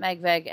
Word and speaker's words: MegVeg: But at MegVeg, MegVeg on Twitter. It's Meg and Veg, it rhymes. It's not MegVeg or MegVegetarian or whatever you want to say. They rhyme, I promise.